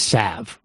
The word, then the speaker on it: salve: In addition to me mispronouncing solve, apparently, there's no L in salve. I'm just going to do it solve. – I thought it was solve, too. – It's salve, but I prefer to say solve.